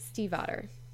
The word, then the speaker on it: Stiefvater: by Maggie Stiefvater.